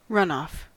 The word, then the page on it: runoff: American English